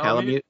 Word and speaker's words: Palamute: These include Sonic themed clothing for the main hunter character, as well as a Tails outfit for the player's uh, Palamute. Is that am I pronouncing that correct? That's right. Correct, yeah, Palamute. Palamute.